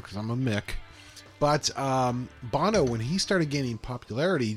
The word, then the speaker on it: Bono: I've been mispronouncing it this whole time. – Bono. – Oh, it is Bono.